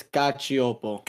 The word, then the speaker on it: Gachiopo: Because you only say the ch sound if there's an I. – Okay. – So it's Gachiopo. – Gachiopo. – And make sure to pronounce the P.